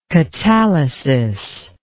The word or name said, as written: katalysis